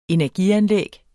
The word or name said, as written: energianlæg